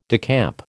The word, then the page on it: decamp: American English